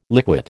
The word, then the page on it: liquate: American English